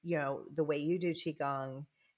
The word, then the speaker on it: Qigong: So, having said all of that, one of the things that that we visited about is you teach, Qigong. Am I saying that right? – Yeah. – Okay. – Qigong.